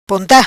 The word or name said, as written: Ponta